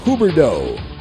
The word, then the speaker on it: Huberdeau: Jonathan Huberdeau.